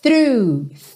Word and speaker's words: through: O GH do final é mudo e through é fechadinho. Lembra só de começar fa com a linguinha lá na ponta dos dentes da frente.